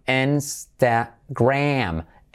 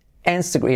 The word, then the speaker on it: Instagram: Instagram. Insta, y como E, letra clave R, Instagram. Y la, esa A casi como una E, Instagram. Instagram. Y el acento es como en la N, Instagram. Instagram.